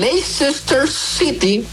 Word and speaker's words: Leicester City: Vámonos hasta Inglaterra, donde el Leicester City.